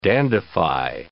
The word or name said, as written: dandify